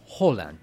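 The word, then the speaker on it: Haaland: Ay, sí, ¿cómo se pronuncia? – Es Holland.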